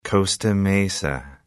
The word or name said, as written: Costa Mesa